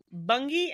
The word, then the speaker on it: Bungay: I looked up the pronunciation and then I wrote it out phonetically on my thing. Bungay and Blythburgh.